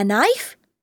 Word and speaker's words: knife: Oh, you mean a knife? K-N-I-F-E. There are lots of words with silent letters in English, aren't there?